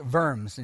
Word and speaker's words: Worms: He was asked to appear before the Diet of Worms, which is just a council, pronounced Worms in German, which included King Charles V.